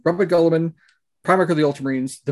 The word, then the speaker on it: Guilliman: So I'm still going by the official Graham McNeill uh, pronunciation of Robert Guilliman.